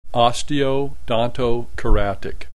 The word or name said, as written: Osteodontokeratic